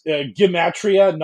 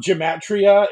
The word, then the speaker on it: Gematria: it is Gematria, not Gematria. It's a hard G, and that all us Philistines that have been talking about -48 have been bricking that.